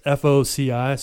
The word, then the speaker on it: FOCI: I would say with the glass blowing, so we went to the, I'm going to butcher the name, but it's FOCI, so Foci, Minnesota Center for Glass Arts.